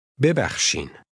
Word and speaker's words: ببخشین: Notice the middle sound, which does not exist in English. Repeat this word after the speaker, trying to make your pronunciation sound like his. Always repeat aloud. – ببخشین.